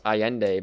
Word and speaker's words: Allende: I need to help my sister escape from Mexico or the tyrants, whatever, Allende, who actually is pronounced Allende by Reyes, as we'll soon learn. So yes, it is Allende. Like, if it's a double L, it should be the y sound.